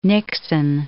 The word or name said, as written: Nixon